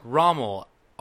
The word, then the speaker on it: Rommel: Rommel. R-O-M-M-E-L. Okay. And it says he will soon begin his rigorous training to support the community by locating missing persons, fugitives, and evidence. Welcome aboard, Rommel.